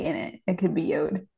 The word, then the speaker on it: Yod: It's spelled Y-O-D. I've always heard it called Yod, but logically it would make sense to be Yod, and it would only be Yod if there was an E at the end, but that's what I've always heard it called.